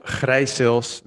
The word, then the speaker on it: Gryseels: Michael Gryseels, that's the correct way to pronounce it.